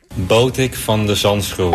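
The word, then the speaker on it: Botic van de Zandschulp: Boutique van de Zandschulp. How's that? – It's not terrible. – That's how it was written phonetically. – Botik van de Zandschool.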